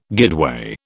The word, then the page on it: guideway: British English